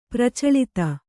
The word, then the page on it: ಪ್ರಚಳಿತ: Kannada